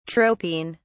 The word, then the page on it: tropine: British English